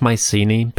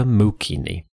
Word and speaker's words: Mycenae: I'll call it Mycenae because that's the most common version in English, but just know that the original pronunciation is closer to Mukini.